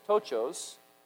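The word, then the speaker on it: tochos (πτωχός): It is tochos, the P is silent, which describes absolute and abject poverty.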